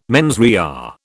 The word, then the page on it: mens rea: British English